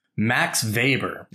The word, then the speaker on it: Max Weber: and crucially, his name is pronounced Max Weber. – That's right. Mr. Weber. – But it's spelled Weber.